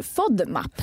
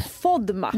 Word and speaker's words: FODMAP: Och idag så ska vi prata om FODMAP. Är det så du uttalar? – Faktiskt inte. Det kallas för FODMAP. – FODMAP.